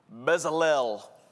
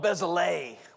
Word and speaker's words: Bezalel: Now listen, if I was doing my Georgia accent reading this name, I would call this name Bezalel. But I looked it up, and that's not how you say it. It's called Bezalel, which doesn't make any sense to me at all, but that's Hebrew.